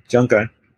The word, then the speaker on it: Jianggan: Jianggan, in Mandarin, pronounced differently in my dialect, which is the tongue of a small town near Shanghai, and it's kind of transitional between Mandarin and Shanghainese,